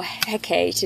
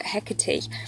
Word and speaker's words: Hecate: The goddess that is associated with this moon is the goddess Hecate, or Hecate, it depends how you pronounce it, Hecate, who is the goddess of the crossroads.